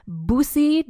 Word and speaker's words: Bussy: And the next person in the was he her lover or not is the famous Bussy d'Amboise. That is spelled first name B-U-S-S-Y.